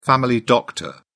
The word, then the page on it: family doctor: British English